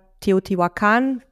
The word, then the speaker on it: Teotihuacan: Nächste Etappe, Teotihuacan oder Teotihuacan, wie es oft ausgesprochen wird, war eigentlich ursprünglich gedacht, um auszuruhen und zu arbeiten.